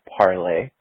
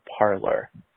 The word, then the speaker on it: Parler: Parler is a French word meaning to talk or to speak. It is pronounced parler, not parlor.